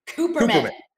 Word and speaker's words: Kuperman: It's Katie Kuperman. Right, Katie? – Kuperman.